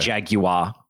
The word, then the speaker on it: Jaguar: That's a Jaguar. – Yeah. – Or a Jaguar. To use the British pronunciation.